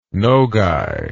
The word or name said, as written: nogay